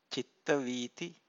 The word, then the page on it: citta-vithi: Pali